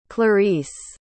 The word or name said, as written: clarice